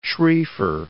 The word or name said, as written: Schrieffer